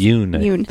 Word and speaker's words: Yeun: Yeun.